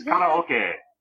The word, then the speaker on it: karaoke: Oh, karaoke! Man. – That's like the worst American pronunciation of a Japanese word. My sister said that, and I'm like, what the hell are you talking about? It's karaoke.